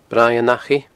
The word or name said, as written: Braigh an Achaidh